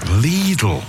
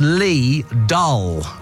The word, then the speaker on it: Lidl: Lidl is pronounced Lee Dull, with the first syllable like the English word Lee and the second sounding like dull. It's a German name, and the I in German is pronounced as a long E sound.